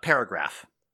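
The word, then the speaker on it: §: It's pronounced paragraph.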